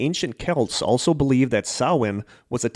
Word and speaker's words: Samhain: And that it is not pronounced how it is spelled. – It is pronounced Samhain. – Yes. – It's spelled Samhain. Movies like the original Halloween get it wrong, or the names of certain Wisconsin independent wrestlers get it wrong.